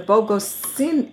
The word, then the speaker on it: Bogocine: Well, now your film, AGATHA, will have its official release in conjunction with the screening down in Bogota, Colombia, at the Bogocine 37? Am I pronouncing it correctly? – Mm-hmm. – Okay. – Yes, correct.